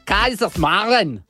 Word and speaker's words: Kaiserslautern: Si pronuncia Kaiserslautern.